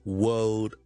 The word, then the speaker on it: world: 有听众问一个好似好简单嘅字，可唔可以讲下 world 呢个字嘅发音咧？World, W O R L D，世界，world。最难嘅地方咧系 W O R L D。如果你系讲英式英文啦，个 R 系唔需要读嘅，就咁 word 咗先，word 咗先。